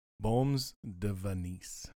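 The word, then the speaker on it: Beaumes-de-Venise: Baumes de Venise.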